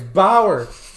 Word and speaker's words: Bauer: I can't even say his name right. It's Bauer. I cannot stand him.